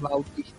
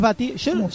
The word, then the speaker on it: Bautista: Bautista, para, no es Bautista. – Es Batista. Se escribe Bautista, pero todo lo dicen Batista. Lo sé. – Ah, bueno, pero dice Bautista. – Es Bautista. Bautista se escribe, pero todo el mundo dice, bueno, Dave Batista.